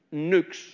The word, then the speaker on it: Nyx: And if you were spelling it in the English language now, it would be the letters N-Y-X. But it's pronounced Nux. N-O-O-X. Nux was the father of Moros. And when you look at the word night, N-I-G-H-T,